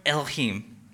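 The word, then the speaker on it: Elohim (אֱלֹהִים): It's the word Elohim. Can I hear you say Elohim? Elohim. You're going to learn like three Hebrew words today. This is the first one Elohim.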